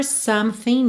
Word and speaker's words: something: Something, que a gente tem uma letra E não pronunciada aí no meio, certo?